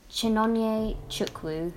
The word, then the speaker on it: Chinonye Chukwu: I can't pronounce her name. Chinonye Chukwu, who is also a woman. – Well, you did well with that, I've got to say. – Thank you.